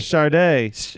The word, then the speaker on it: Sade: Sade.